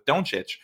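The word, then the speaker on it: Dončić: And it was, in this case, it was with Doncic, and they were immediately.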